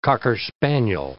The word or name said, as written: cocker spaniel